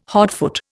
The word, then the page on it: hotfoot: Indian